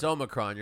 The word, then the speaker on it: Omicron: This was when that whole Omicron thing was, Omicron or Omicron? – It's Omicron. You're saying it right.